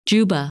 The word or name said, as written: Dschubba